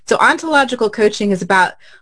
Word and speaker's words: ontological: What is an ontological coach? And did I say it right? – You said it absolutely correctly.